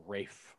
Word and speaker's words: Ralph: Is it Ralph Fiennes? Ralph Fiennes? – It's spelled Ralph, but they pronounce it Ray. – Yeah, or Rafe.